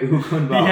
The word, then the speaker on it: Ogunbowale: Ogunbowale. – Ogunbowale. – Ogunbowale. – There's no L in there. – The L silent. All right, so Dare Ogunbowale.